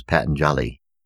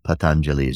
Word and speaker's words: Patanjali: Patanjali, sometimes mispronounced in the West as Patanjali, but Patanjali is the proper pronunciation.